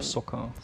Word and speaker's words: Socha: Ich glaube, ähm, Soccer. Soccer.